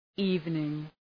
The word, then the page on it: evening: ‘i:vnıŋ